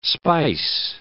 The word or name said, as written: spice